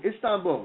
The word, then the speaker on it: Istanbul: I got to say this right because my girlfriend keeps correcting me. Istanbul, No, you said in. – Istanbul. Istanbul, right? – Yep.